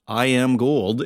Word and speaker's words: IM Gold: it is pronounced I Am Gold, and I got the full story on that, and it's more than you might expect.